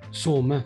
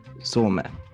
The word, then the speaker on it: Soume: Hur uttalar man ditt efternamn? Chris? Some. Some.